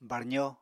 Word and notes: barzo: Apatani

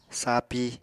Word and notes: sapi: apatani

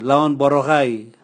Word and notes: lanon borogai: Raga

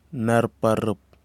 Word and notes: narparip: kwamera

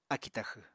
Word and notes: akì taxw: apatani

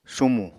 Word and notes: sumù: apatani

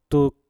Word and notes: tuk: mpur

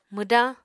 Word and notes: mwdañ: Apatani

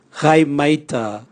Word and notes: gaimaita: Raga

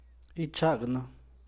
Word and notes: ica yu gel: San Mateo Macuilxochitl Zapotec

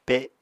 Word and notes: pe7: Apatani

